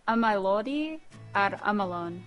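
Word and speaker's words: Ymaelodi â'r Ymylon: And I don't know how to say this or what it means, but I would guess Imiladi ar Yimilon. But how do you pronounce that? – You'd say Amilodi. I'm alone.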